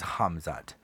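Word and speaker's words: Khamzat: His name is Hamzat. Hamzat. It's not Kamzat, but it's like kind of pronounced that way if you're going to say it in English because it starts with a K. But Hamzat. So he's the big up-and-comer.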